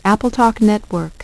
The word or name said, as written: AppleTalk Network